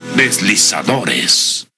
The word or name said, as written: Deslizadores